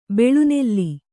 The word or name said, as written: ಬೆಳೆನೆಲ